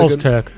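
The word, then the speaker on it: Öztürk: Alim O'Turk from some Turkish club that I can't pronounce, Boluspor, Tom Flanagan, Oz-Turk. – That's the one.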